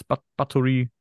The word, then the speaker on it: Báthory: Die 1560 geborene Elisabeth, wie spricht man es aus? Báthory.